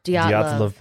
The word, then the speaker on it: Dyatlov: Dyatlov? – Dyatlov Pass.